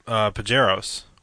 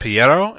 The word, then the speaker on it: Pajero: Just the, like, oh, the Pajeros, which are, well... I started saying Piero, and he was like, what? And I was like, Pajero, because it's a hard J. – Yeah, they say a hard J.